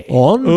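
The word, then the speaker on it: Ondřej: Like the American was A-N-D-R-E. This Andre Palat is O-N-D-R-E-J. – Andre. – Ooh, the J is silent. – Andre. – It's soft J. – Exactly. Real soft J.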